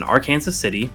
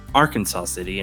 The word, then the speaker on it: Arkansas City: This group is based in Arkansas City, not Arkansas City, and yes, they had to correct my pronunciation, although you'll notice they normally just abbreviate to Ark City, which does make it easier.